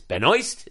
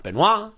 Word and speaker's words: Benoist: That's how you say it. It's Benoist. It's not Benoit. It's not Benoist.